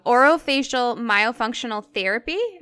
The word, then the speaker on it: orofacial myofunctional therapy: And you also specialize in, and I'm probably going to butcher this as well, so correct me where I'm wrong, orofacial myofunctional therapy. Am I saying it right? – That's right, you got it.